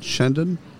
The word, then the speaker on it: Chandan: Chandan? – Chandan, yes.